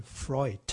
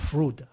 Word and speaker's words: Freud: 他读音应该念 Freud，不是 Freud 啊。那个德文 D 念 T 啊，EU 发 OI 啊。